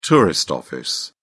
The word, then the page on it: tourist office: British English